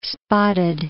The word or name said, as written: spotted